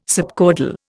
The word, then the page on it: subcaudal: Indian